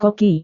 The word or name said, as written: koki